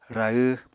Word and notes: rahw: Apatani

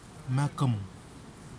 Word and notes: makhum: kwamera

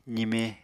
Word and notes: zime: apatani

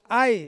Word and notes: ai: Ansus

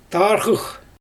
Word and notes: tarɣɯx: Xyzyl

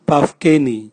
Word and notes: bavkeni: Raga

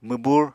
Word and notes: mwbur: Apatani

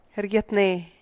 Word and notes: re: rèe’eh

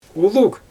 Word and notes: ulug: Xyzyl

